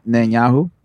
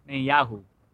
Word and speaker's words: Netanyahu: Netanyahu. – Netanyahu.